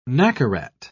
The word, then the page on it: nacarat: British English